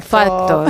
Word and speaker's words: fact: Lo de pronunciar fact, que al final tienes que obviar la C e irte por la T, porque si no te sale la otra palabra que sin querer hubiésemos pronunciado, y a lo mejor si nos hubiese escuchado alguien de Kansas City nos hubiese llamado, oye, por favor, no estáis diciendo lo correcto.